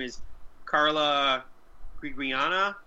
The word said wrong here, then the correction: Gugino: Is that her name? – Gugino.